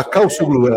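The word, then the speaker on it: Çavuşoğlu: É, ah, ah Calçuglu. – É, é, é exato. Então, você não pronuncia o V, exatamente.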